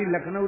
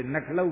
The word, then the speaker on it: लखनऊ: ब्रजवासी लखनऊ नहीं बोलते, नखलऊ बोलते हैं।